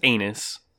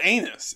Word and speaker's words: Annus: Where Unus Anus is not Unus Anus, like you like to say. – But it's spelled anus. It's no, literally it's anus. – Not. No, it's not. It's Anus. A-N-N-U-S.